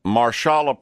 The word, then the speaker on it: Marshalla: Her name is Marshalla Perkin. I think the S is silent. – Yep. Yeah.